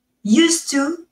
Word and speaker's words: used to: Ahí sí no me voy a cansar de insistir porque, claro, used to tiene una D al final que se tiene que oír y se oye como una T suave.